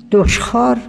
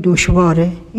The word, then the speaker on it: دشخار: در کتب قدما، نشر و نظم پیشینیان، دشخار بسیار میبینید. دشخار، این امر دشخاره. و الان ما تلفظ میکنیم دشواره. اینم صحیحه.